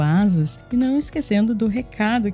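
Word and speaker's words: Asaas: Lembrando que Asas se escreve A-S-A-A-S e conheça a conta conta digital completa para empresas.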